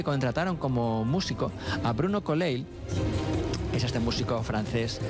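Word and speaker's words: Coulais: Este es Colleil. – Bruno Colleil. – Como si fuera del Barça. – Es culé, culé.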